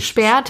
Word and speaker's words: Speer: Aber spricht man das auch Speer aus oder ist es auch Spiel? – Nee, nee, das ist Speer, das sind Österreicher.